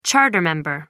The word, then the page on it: charter member: American English